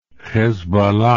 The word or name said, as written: hezbollah